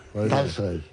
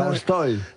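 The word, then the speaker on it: Tolstoy (Толстой): Tolstoy. – Las palabras que se escriben en O en ruso, pero van acentuadas, se pronuncian casi A.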